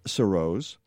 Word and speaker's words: Surroz: So back to Sarah Surroz. Let's and that is how you pronounce your last name, right? – Yep. – With the long O?